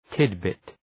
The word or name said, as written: tidbit